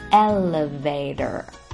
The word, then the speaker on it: elevator: 美式英语中呢，一般会把这里的第二个字母 E 发得比较像 A 的声音，而字母 T 在这里发得则比较像 D, D 的声音，所以连起来整个单词念作 Elevator, Elevator. 好嘞，